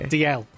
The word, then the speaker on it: Dalziel: DL. – DL. – Yeah, Scottish. DL is pronounced. Oops. I know it looks like a Z, but it's DL and Pasco.